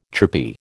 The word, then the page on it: chirpy: American English